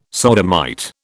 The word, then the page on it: sodomite: British English